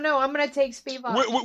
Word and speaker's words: Spivak: I think Lewis is still going to win because I think the guys at Spivak, how do you pronounce his name? – Spivak. – Spivak.